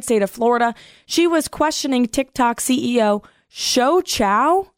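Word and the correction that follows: Shou Chew: and I may be butchering the pronunciation of that name, Taylor. – I think it's Shou Chu.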